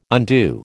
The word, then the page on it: undo: American English